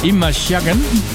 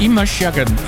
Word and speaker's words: Imashagen: Imashagen. – Imashagen. – Ja, ik had het goed uitspreken. Imashagen. I-M-A-S-H-A-G-E-N.